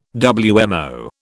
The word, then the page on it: WMO: British English